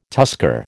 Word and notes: tusker: American English

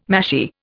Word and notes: mashie: Indian

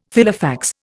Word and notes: Filofax: Indian

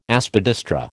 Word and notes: Aspidistra: American English

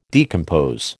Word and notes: decompose: American English

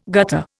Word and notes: gutter: Indian

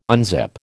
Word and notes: unzip: American English

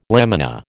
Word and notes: lamina: American English

